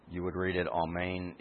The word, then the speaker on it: amen: Also, I think you know what's happening here, but also that I pronounce this word amen twice. This is the way it would be pronounced in both Hebrew and in Greek.